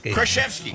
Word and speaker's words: Krasinski: Did I pronounce it wrong all the time? – Krasinski. Yeah, there you got it.